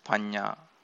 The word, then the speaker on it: panna: One's panya or one's wisdom will grow. Note that panya is spelled P-A-N-N-A. It is pronounced panya.